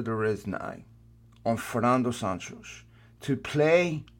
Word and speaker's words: Santos: Because if you're Fernando Sanches, um, did I say that right? Did I say uh, you're you're muted? – Sorry about that. Sorry. – Yeah. did I did I say it right? Fernando Santos. Yes, that's correct. Santos.